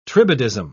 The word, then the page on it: tribadism: British English